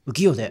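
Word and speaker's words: UQiYO: さて、今回第 52 回のゲストは、バンド浮世の加藤祐樹さんです。はい、加藤祐樹です。よろしくお願いします。お願いします。浮世で発音合ってました。 – 浮世で合ってます。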